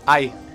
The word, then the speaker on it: Ai: Okay, my name is Ai. – Ang? – Ai. – Ai. – Yeah. – How do you spell that, Ai? – A-I. – Literally, A-I.